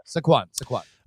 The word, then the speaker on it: Saquon: I'm not going to say his first name right. Saquon Barkley. Am I saying it right? Maybe I did. – Saquon.